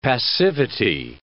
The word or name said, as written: passivity